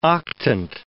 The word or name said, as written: octant